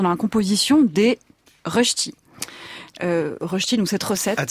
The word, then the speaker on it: Rösti: R au tréma, donc R-S-T-I, ch'ti. – La prononciation est bonne ou pas ? – Rösti. – Rösti. – C'est ça ? Ouais.